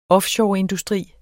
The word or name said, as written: offshoreindustri